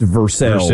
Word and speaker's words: Versailles: It's like Versailles being pronounced Versailles in Kentucky, which has totally, that threw me for a loop for a couple of days.